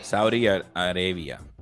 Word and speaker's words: Saudi Arabia: Ay, Dios mío, South Arabia, puppy. South Arabia. – Saudi Arabia.